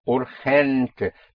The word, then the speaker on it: urgente: And in Spanish, the G before E and I is pronounced like in Scotland, the Loch Ness. Loch. Like it's. So urgent would be urgente. Urgente. But spelled with a G.